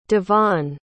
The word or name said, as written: davonne